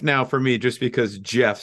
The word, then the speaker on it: GIF: It is pronounced Jeff. It's Jeff. – Okay, Jeff. See, exactly. – I know it's Jeff. It's like peanut butter.